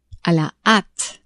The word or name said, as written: a la hate